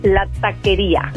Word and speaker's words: La Taqueria: And am I saying it right? La Taqueria? – La Taqueria. – La Taqueria. There, that sounds better. That sounded way better than the way I was doing it.